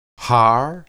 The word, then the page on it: har: hebrew